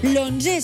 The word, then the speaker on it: l'onze: L'onze s'apostrofa perquè encara que estigui escrit en números, es pronuncia amb una O, l'onze, i per tant, s'apostrofa.